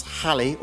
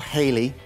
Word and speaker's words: Halley: How often does Halley, or Halley, depending on your pronunciation, how often does Halley's comet pass the Earth? – This is a trick question.